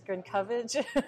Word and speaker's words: Grzenkowicz: Yes, my last name is Grzenkowicz. – See, I would have butchered it. I would have been something, you know. – Most of those letters are silent.